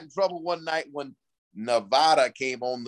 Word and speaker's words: Nevada: It's Nevada. It's Nevada. I had to learn that the hard way, Pat. My producer says at halftime, hey, Houston, our phones are lighting up, our emails are lighting up, our Instagram's lighting up. They're wanting Coach Nutt to learn how to pronounce Nevada.